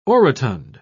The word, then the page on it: orotund: British English